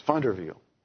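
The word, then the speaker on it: VanderWeele: It's a Dutch name, and the V's are pronounced like F's, and the W's are pronounced like V's, I guess. Funderveel.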